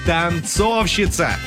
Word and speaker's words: танцовщица: Танцовщица. О, как я танцую. Вот, на «о» ударение.